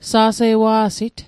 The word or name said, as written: sa'se'wa'sit